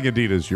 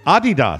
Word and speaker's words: Adidas: Regarding Adidas, your mother was actually pronouncing the name correctly.